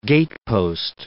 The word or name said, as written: gatepost